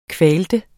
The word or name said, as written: kvaltes